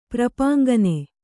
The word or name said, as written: ಪ್ರಪಾಂಗನೆ